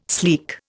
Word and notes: sleek: Indian

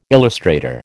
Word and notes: illustrator: American English

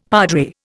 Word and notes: padre: Indian

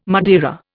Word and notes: Madeira: Indian

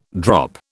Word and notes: drop: British English

